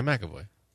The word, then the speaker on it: McAvoy: Is James McAvoy? – Sure. Randy's brother? – No, it's McAvoy. – Yeah, McAvoy.